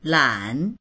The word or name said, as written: lan3